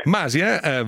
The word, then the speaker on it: Masia: Masia va con l'accento sulla I, perché sì, è Masia.